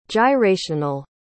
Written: gyrational

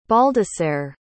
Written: baldasarre

